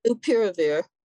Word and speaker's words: Molnupiravir: Molnupiravir. – Yeah, say that again. It's Molnupiravir.